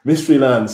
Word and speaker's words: Mysteryland: Si alguien quiere ir al Mysteryland, ¿cómo deletreas Mysteryland? – Wow, Mysteryland. Eso es incluso más complicado que Glastonbury, Johnny. Mysteryland sería M-Y-S-T-E-R-Y. And then land, L-A-N-D.